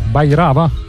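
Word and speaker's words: Bhairava: In the End, la seconda traccia di questo nuovo lavoro chiamato Bairava. Dico bene, Y? Bairava? – Sì, sì, perfetto.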